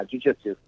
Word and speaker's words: jiu-jitsu: You said you own I'm going to say it wrong, but is it jiu-jitsu? – Yeah, jiu-jitsu.